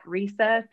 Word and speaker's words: Risa: You can also so you can tweet at me at Risa Pear Deck,